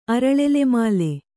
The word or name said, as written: ಅರಳೆಲೆಮಾಲೆ